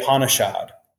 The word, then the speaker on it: Upanishad: It's the Upanishad. – Upanishad.